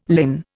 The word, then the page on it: linn: Indian